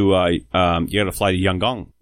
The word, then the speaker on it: Yangon: You said it so right. Your pronunciation was on. – Right. Yeah, Eric's right. – No, the chief's like, yeah, you got to fly to Yangon.